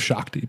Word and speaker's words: Shakti: So yeah, this is dedicated to Shakti. I believe it's how it's pronounced. S-H-A-K-T-I. So I think it's Shakti.